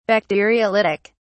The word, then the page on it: bacteriolytic: British English